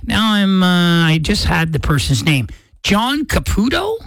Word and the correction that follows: Caparulo: Am I saying that right? It's Caparlo, C-A-P-A-R-U-L-O, Caparlo.